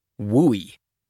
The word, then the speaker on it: WUI: That's the goofily named Wildland Urban Interface, which is pronounced, even more goofily, WUI.